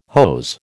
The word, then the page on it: hose: American English